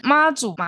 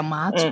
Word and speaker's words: 马祖: 嗯，对，但还可好。我们来讲一下，我先来讲一下，就是推荐的两间餐厅，就是妈祖马马祖，好，不要讲妈祖，因为那边也有妈祖，我们讲马祖。